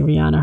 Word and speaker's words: Rihanna: got that award, by the way, which was presented to her by Rihanna, or, you know, us Americans say Rihanna. Her real name is Rihanna. I guess that's the real way to pronounce her name.